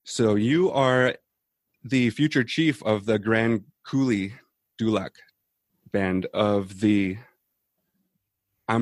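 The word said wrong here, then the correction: Grand Caillou: That's almost right, yeah. It's the Grand Cayu Dulac band of Biloxi Chittimacha Chokta.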